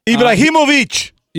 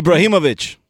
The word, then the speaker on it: Ibrahimovic: So Zoltan, I believe his last name, I'll try to say this properly, Abramovich. – Ibrahimovic. – Yeah. What's his name? Ibrahimovic? – Ibrahimovic.